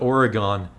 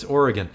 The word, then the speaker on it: Oregon: It always bugs me when people mispronounce that. Like, it's not Oregon, it's Oregon. So it's that Pacific Northwest regional horror.